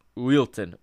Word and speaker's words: Wilton: on a ball that was sent in by Zé Utilian. Wilton. Wilton is how you say it.